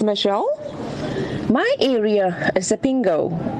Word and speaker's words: Isipingo: Most visitors, well, unfortunately, they cannot pronounce the name as Sepingo. And they say Isipingo and a whole array of other mispronunciations. So we locals, to make it easy on them, we simply say, just say Spingo. You're coming to Spingo.